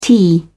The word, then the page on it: tee: British English